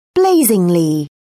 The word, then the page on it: blazingly: British English